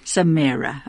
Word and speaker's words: Szemere: His surname is spelt S Z E M E R E. Maybe you can pronounce it. But how did you say you pronounce it, George? Just tell me. – Samara. – Samara, okay.